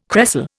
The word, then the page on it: cresol: Indian